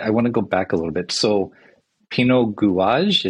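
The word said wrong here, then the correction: Gouges: is that how you say it? – Pinot Gouge. – Gouge. I'm sorry.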